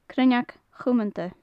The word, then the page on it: cruinneag chumanta: gaelic